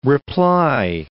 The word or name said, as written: reply